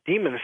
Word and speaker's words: daemons: Demons is... it's an ancient Greek term. The original was daemons, D-A-E, but pronounced as we would still pronounce demons.